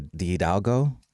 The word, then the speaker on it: de Hidalgo: There's a conversation with Maria's teacher, Elvira de Hidalgo.